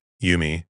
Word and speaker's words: UMI: Kakehashi favored the name Universal Musical Interface, UMI, pronounced Yumi, but Smith felt this was a little corny.